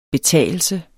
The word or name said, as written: betagelse